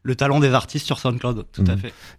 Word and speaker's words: SoundCloud: Ça s'appelle SoundCloud. Je prononce pas bien. – Tout à fait. SoundCloud. Non, SoundCloud, vous prononcez bien.